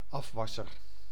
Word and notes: afwasser: /ˈɑfʋɑsər/; /ˈɑfwɑsər/

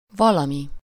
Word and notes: valami: /ˈvɒlɒmi/

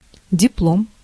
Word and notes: диплом: /dʲɪpˈlom/

